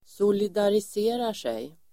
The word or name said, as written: solidariserar sig